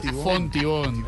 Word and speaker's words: Fontibón: No, en la localidad de Hontibón. – Fontibón, Edward, Fontibón.